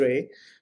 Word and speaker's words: RAI: And just to make sure everyone understand Ray, the organization that Manoj has founded, Responsible AI Institute, RAI, is just pronounced as Ray.